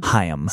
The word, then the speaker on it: Haim: Haim. – See, I've always said Haim. – I've always said Haim. – And I only learned it because it's like La Haim.